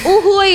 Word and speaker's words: Uy Hui: Ini gimana sih bacanya? – Uhui ya, aduh yeah. Karena kayak uy, spasi hui gitu yeah.